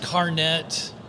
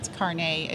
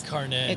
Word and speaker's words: carnet: So it's not pronounced carnet like a lot of people think? – No, not like a hairnet. No, it's carnet. It's carnet.